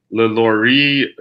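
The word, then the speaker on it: LaLaurie: but I think it was like Madame Lelorie, if I'm mispronouncing her name. – No, I believe that's right.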